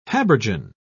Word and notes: habergeon: British English

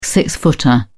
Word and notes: six footer: British English

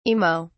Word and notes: imo: British English

